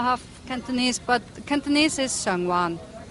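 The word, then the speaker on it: Sheung Wan: However, it's not Sheng, it's Sung. – Sung. – There's no sh in Cantonese. So, why do I spell it with SH? I do not know, and it's a pet peeve uncovered.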